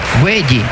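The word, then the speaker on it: Guéhi: Mar Gueye, o más bien con el acento en la E, Gueye.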